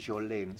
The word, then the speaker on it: Jolene: Hay un tema de ella que creo que se pronuncia Jolene. Se escribe Jolene. – Ah, sí. – Jolene, ¿no? – Sí. – Jolene.